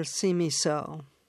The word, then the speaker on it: Simiso: So tell us about your character's destiny and her mother, Simiso. Or uh, is my, how do I pronounce her mother's name? – You pronounced it perfectly. It's pronounced how it's spelled.